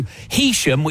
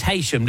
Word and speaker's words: Heysham: Heesham, which everybody pronounces Heysham, don't they? – Yeah, everyone says it's Heesham. – It is Heesham.